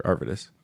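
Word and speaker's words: Arvydas: Arvidas, is that right? Arvidas? – Sure, Arvidas.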